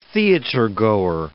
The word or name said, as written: theatergoer